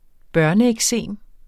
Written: børneeksem

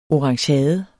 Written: ORANGEADER

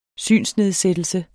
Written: synsnedsættelse

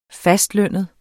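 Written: fastlønnede

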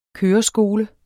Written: køreskole

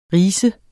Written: ris

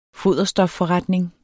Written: foderstofforretning

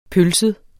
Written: pølset

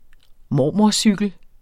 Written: mormorcykel

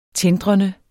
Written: tindrende

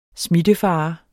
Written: smittefare

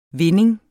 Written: vinding

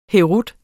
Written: herut

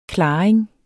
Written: klaring

